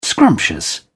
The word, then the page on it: scrumptious: British English